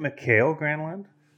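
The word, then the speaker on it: Mikael Granlund: Was there, so you're all on the Michael Granlund train, but was there someone else that, what? Isn't it Mikhail Granlund? – Sure. Yes. Sorry.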